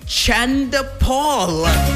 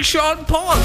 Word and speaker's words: Chanderpaul: He's saying Chanderpaul. He's not saying Sean Paul.